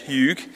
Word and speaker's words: hygge: I'm reliably informed by YouTube that that is how you pronounce H-Y-G-G-E in Danish, Hyug.